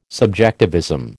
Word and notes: subjectivism: American English

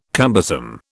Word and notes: cumbersome: British English

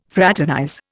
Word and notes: fraternize: Indian

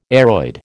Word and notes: aroid: American English

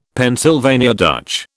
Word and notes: Pennsylvania Dutch: British English

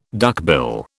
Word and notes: duckbill: British English

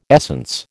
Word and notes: essence: American English

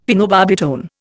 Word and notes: phenobarbitone: Indian

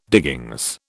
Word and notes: diggings: British English

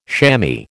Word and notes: shammy: American English

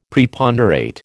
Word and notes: Preponderate: American English

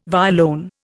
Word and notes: violone: Indian

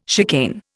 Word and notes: chicane: Indian

